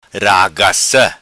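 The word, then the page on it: Ragaca: patua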